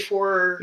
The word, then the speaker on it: Ejiofor: Tweedle Ejiofor.